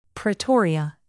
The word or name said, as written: pretoria